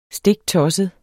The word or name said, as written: stiktossede